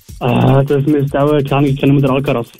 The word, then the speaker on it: Alcaraz: Alvarez. – Alcaraz, ja.